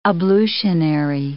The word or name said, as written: ablutionary